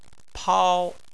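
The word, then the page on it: pao: tone 1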